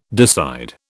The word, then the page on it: decide: British English